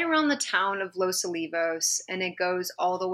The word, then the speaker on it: Los Olivos: Or Los Olivos. It's pronounced Los Olivos, right? – It's pronounced Los Olivos, yeah. – I always do that wrong.